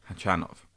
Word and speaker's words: Khachanov: Khachanov. – Yeah, I'm pretty sure the stress on the second syllable.